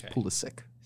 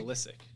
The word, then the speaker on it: Pulisic: I thought it was Pulisic. – I wish, but it's Pulisic. – Sick? – Yeah. Pulisic.